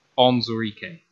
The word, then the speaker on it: Onwuzurike: And my nice little hint for how to pronounce this guy's name is take the woo out of his name and then say exactly what's there. Onwuzurike.